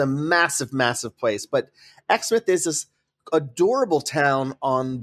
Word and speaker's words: Exmouth: Yeah, Exmouth.